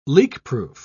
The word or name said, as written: leakproof